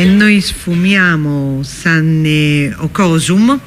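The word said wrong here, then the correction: Sonny Okosun: oppure come si pronuncia? – Sonny Okosur, che poi il brano è Which Way Nigeria, che certo.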